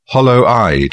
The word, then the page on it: hollow-eyed: British English